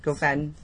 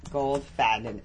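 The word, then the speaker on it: Goldfaden: I want to get to Dr. Goldfob... – Gofadden. – Right. How do you spell that? – G-O-L-D. Goldfadden. F-A-D-E-N.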